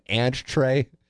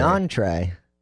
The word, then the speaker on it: entree: Guys, did I happen to notice Plowsy just called an entree an ant tray? That's from Stubby Clap. You did pronounce it differently, but I wouldn't say it was an ant tray. – An entree.